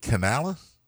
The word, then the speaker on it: Canales: Dave Canales? – I guess that's how you pronounce it. – Yeah, it is.